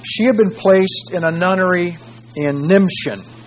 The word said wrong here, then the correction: Nimbschen: Nimbschen. Nimbschen. A Cistercian nunnery near Leipzig. A Cistercian, I think I spell that, C-I-S-T-E-R-C-I-A-N, near Leipzig.